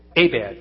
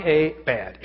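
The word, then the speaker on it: abad (עבד): When I looked at the word work in that passage, the Hebrew word for work is abad, long A, bad, abad.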